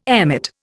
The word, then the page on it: emmet: Indian